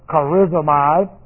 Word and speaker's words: charizomai: But one word is charizomai. And this is C-H-A-R-I-Z-O-M-A-I.